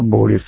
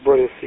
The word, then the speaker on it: Борис: Ну вот, и там именно говорят, что это Борис, а не Борис, не на последнюю букву ударения Борис.